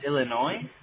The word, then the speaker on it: Illinois: Dude, there's no S. You don't pronounce the S. – I don't? So it's just Illinois? – Yeah.